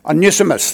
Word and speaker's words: Onesimus: And one of his slaves was a man called Onumus. I don't know how to pronounce his name. Onesimus, thank you.